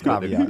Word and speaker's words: caveat: Is the word caveat? – Yeah. – Okay. I want to throw a caveat. So, fact check. Can we get our fact checker to fact check the pronunciation of that? – It's caveat. – Pronunciation, that's the only thing I needed. I knew what the term meant. – It's caveat. – The actual pronunciation.